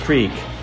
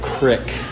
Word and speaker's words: creek: The word creek, it's pronounced creek. She, to her dying breath, said crick. – Crick. – I go, there's two E's.